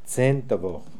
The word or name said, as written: Dzendvokh